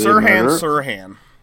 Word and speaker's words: Sirhan: Sirhan, the man convicted for the Kennedy Sirhan. Yeah, it's sounds more like it's spelled than you would think.